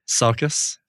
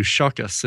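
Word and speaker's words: Csokas: Yeah, I don't think you don't pronounce one of those. You don't pronounce the C and the S. I think you just go Sokas.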